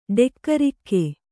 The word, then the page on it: ಡೆಕ್ಕರಿಕ್ಕೆ: Kannada